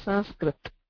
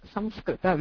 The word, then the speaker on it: Sanskrit: If you hear the uchcharana of Uttareyas, that is the North Indians, they pronounce the, they prefer the anunasika form, which is sanskrit, with an nasal intone for the akara, whereas the southerners pronounce it as samskrita with the anuswara form.